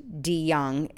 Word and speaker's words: DeJong: His name is Mendert DeYoung, capital D-E, capital J-O-N-G. Mendert DeYoung, it's pronounced, but it's spelled D-E-J-O-N-G.